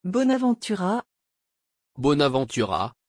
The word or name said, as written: bonaventura